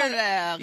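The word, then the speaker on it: Römerberg: We are actually standing in Rummerberg, which my German relatives would probably be disappointed in that pronunciation, but it's considered... – Rummerberg. – Yeah, there you go.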